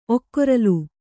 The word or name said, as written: ಒಕ್ಕೊರಲು